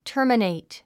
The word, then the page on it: terminate: tə́ː r mənèit; タァマネイト